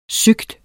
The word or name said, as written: syg